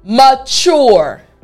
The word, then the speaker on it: mature: So after me, chore. Excellent. So now the entire word after me, mature. Excellent. Last time, mature.